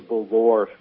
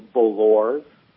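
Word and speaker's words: Bolloré: I mean, who has ever heard of the Belor family? – The what family? – The Belors. – How do you spell it? – B-O-L-L-O-R-E.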